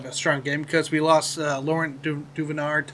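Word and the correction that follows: Duvernay-Tardif: Wait, how did you, how did you pronounce that name? – L- L- Laurent Duvenard. Ted Tardif. – That's close or, enough. Duvernay, du- Duvernay Tardif. Tardif. Yes.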